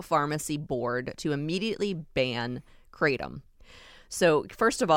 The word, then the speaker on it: kratom: And am I saying it correctly? Kratom? – Yeah. – Okay. – You are. You got it.